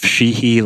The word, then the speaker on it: Sheehey: So you can find myself on Instagram at Joseph Sheehey. Last name is spelled S-H-E-E-H-E-Y.